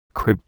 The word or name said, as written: crib